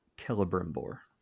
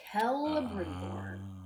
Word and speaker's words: Celebrimbor: And it is Celebrimbor. – Celebrimbor.